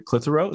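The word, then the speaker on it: Clitherow: And then financially, you picked St. Margaret Clitheroe.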